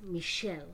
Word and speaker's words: Michel: It's pronounced Michel. – Michel. Michel, my belle.